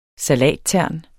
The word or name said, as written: salattern